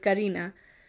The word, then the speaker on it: Karena: So in the early mornings of September 7th, so the next morning, her roommate Karena, her name is spelled Karena, by the way, but it's Karina.